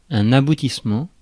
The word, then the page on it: achievement: /əˈtʃiːvmənt/